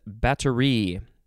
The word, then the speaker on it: batterie: Uh, next word is battery. The emphasis is on the last syllable B A T T E R I E.